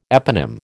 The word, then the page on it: eponym: American English